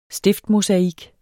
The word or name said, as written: stiftmosaik